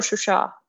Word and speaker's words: Shushi: And Shushi is Shushi or Shusha, if we go with the Azerbaijani pronunciation, is very, very important, you know, culturally for both nations.